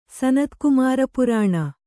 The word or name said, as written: ಸನತ್ಕುಮಾರಪುರಾಣ